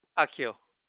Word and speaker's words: Akio: Akio.